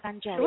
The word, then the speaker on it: Angelica: It would be nice, you know, I like to keep the Romanian pronunciation on the name. So, but it's spelled as Angelica.